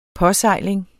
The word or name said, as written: påsejling